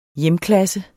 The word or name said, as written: hjemklasse